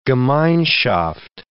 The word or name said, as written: gemeinschaft